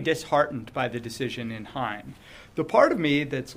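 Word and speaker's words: Heien: It was fortuitous that in the meantime, the court decided a case, I think it's pronounced Hein, H-E-I-E-N v. North Carolina, which was just decided middle of last month, I think December 15th.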